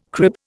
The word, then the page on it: crib: Indian